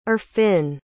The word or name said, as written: irfon